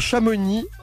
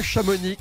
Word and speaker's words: Chamonix: Oh la preuve, on ne le prononce même pas puisqu'on dit Chamonix et pas Chamonix, que ce soit bien clair entre vous et moi.